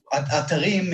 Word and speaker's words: Atarim: So, first of all, I pronounce it some way. Atarim means websites in Hebrew, yeah, which is my native language. And that is what I was going for. But I don't want to force the pronunciation because in the English language, there is no emphasis on the end of the word. So Atarim doesn't exist in English.